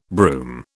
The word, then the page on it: brume: British English